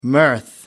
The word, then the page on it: mirth: American English